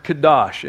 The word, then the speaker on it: kadosh: So the Hebrew word we translate holy there is the word kadosh. If you were to spell it in English, it looks like K-A-D-O-S-H. Kadosh.